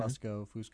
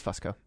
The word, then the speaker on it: Fusco: I think that's how you pronounce his name. Mm-hmm. Fusco. – I think it's Fusco. – Fusco. – F-U-S-C-O.